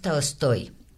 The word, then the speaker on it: Tolstoy: Entonces decimos Tolstoy y Dostoyevsky, pero en realidad, ¿cómo se pronuncian? – Pues mira, Tolstoy es Tolstoy, uh-huh. Dostoyevsky es Dostoyevsky, Gogol es Gogol. Y en una